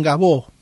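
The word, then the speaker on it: Gaveau: Luego Erhard se transformó en Gabot, ¿sí?, que se escribe Gabeau, ¿sí?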